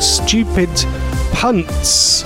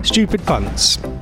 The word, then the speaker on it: Stupid Punts: It was a panel show which has never been repeated again called Stupid Punts. Right, I said it right, with a P, right, with a P. Stupid Punts.